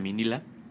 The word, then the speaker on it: Minilla: Eh, eh, ya era un personaje icónico, ya era, ya era bueno, de hecho también tuvo un, hijo que se llamaba Minila, Minilla creo que se, que se escribe, pero sí dice Minila.